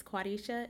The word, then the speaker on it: Quadesha: Please be sure to follow me on all platforms at It's Quadesha,